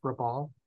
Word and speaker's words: Rabaul: Is that how it's pronounced, Rabaul? – Yeah.